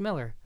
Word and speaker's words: Millar: Well then, he probably got tired of me saying Millar, right? – He went on to Twitter or interview. It's like, no, it's actually pronounced Miller.